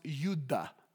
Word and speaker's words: yuda: It's actually pronounced, it's a Greek word that's pronounced yuda.